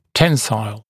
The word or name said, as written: tensile